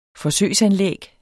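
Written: forsøgsanlæg

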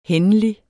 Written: hændelig

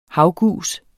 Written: havgus

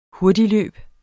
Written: hurtigløb

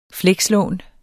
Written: flekslån

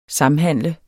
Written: samhandle

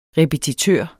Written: repetitør

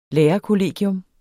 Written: lærerkollegium

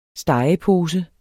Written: stegepose

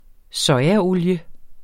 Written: sojaolie

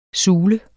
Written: sule

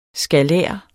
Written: skalær

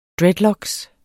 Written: dreadlocks

